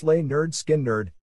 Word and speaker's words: Lynyrd Skynyrd: pronounced Lay Nerd Skynyrd.